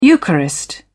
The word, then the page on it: eucharist: British English